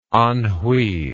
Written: Anhui

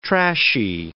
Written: trashy

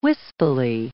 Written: wispily

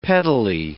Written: pettily

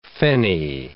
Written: fenny

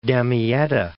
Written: Damietta

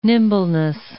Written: nimbleness

